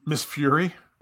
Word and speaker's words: Miss Fury: Check out Miss Furry. Am I saying that right? That's Miss Furry. – Miss Fury. – Fury? All right, Miss Fury.